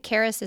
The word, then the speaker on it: Charis: Karis is spelled Charis, and they ship across the country, correct? – Mm-hmm.